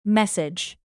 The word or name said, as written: message